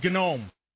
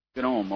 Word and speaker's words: GNOME: How do you say that? – I say it GNOME. – GNOME. Okay. – Because that apparently is the way they do it.